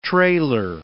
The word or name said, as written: trailer